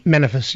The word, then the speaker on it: Manifris: Manifris, yep.